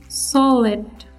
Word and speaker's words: solid: The next word is solid. Solid. Solid. Solid. S O L I D solid S O L I D solid به معنای جامید The stress is on S The stress is on S letter